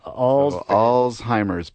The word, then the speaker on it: Alzheimer's: I like to say, remember all's fair. Alzheimer's,